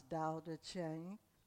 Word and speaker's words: Tao Te Ching: It's Tao, T-A-O, D-T-E, T-E, T like Thomas, Ching, C-H-I-N-G, and pronounce Tao Te Ching. Close enough.